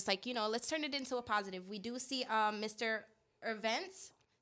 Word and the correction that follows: Ovince: Saint-Per, yeah. If I said that properly. – Yeah. Ovince. Well, we're going to have to work on these last name. – Ovince Saint-Per.